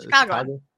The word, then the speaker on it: Chicagoan: So is that how you say it? – Chicagoan. – Yeah. You say it really quickly with the accent. That sounds right. Chicagoan.